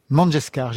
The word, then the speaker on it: Mangeshkar: C'est la chanteuse indienne Lata Mangeshkar. J'ai bien prononcé ? – Mangeshkar. – Mangeshkar, je me disais aussi.